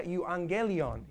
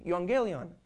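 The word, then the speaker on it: euangelion (εὐαγγέλιον): Y aquí miramos que en la palabra el griego se pronuncia euangelion, euangelion.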